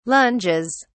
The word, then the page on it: lunges: British English